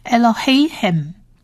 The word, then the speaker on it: Elohim: 你们的神就整体发音就是 Elohim Elohim， Yahweh Elohim 就是耶和华你们的神的意思。